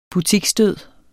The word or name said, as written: butiksdød